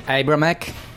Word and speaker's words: Abramek: Last name is Abramek. – Okay, everybody repeats Abramek. That will be in Polish, but obviously when you say in English, you have different accent. You put accent on the wrong.